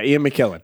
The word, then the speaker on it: Ian McKellen: Ian McKellen, is that how you say it? – Yeah, Ian McKellen.